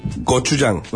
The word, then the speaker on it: Gochujang: Gochuragu, ¿cómo se dice? – Gochujang.